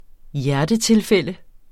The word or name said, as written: hjertetilfælde